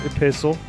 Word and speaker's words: epistle: You're pronouncing it right, Epistle.